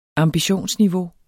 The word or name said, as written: ambitionsniveau